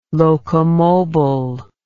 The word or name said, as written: locomobile